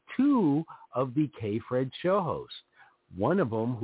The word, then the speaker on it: KPHRED: That's K-P-H-R-E-D, pronounced so it sounds, K-Fred.